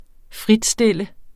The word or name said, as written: fritstille